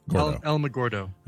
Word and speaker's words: Alamogordo: Alamogordo. – Alamogordo.